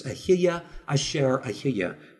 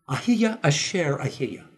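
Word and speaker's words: Ahiya Asher Ahiya (אהיה אשר אהיה): In Hebrew, you would pronounce it like this, Ahiya Asher Ahiya, meaning I am that I am.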